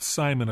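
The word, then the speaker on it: CIMON: And his name is Simon, only it's spelled with a C. Anyway. – Kaiman, but it's pronounced Simon, apparently.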